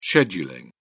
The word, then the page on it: scheduling: British English